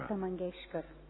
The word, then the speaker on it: Lata Mangeshkar: And it's many of the Indian people here would instantly know the singer, Lata Mangeshkar. I can never get her name right. Lata Mangeshkar.